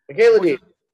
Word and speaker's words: Michaela Dietz: Michaela Dietz.